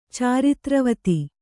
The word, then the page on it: ಚಾರಿತ್ರವತಿ: Kannada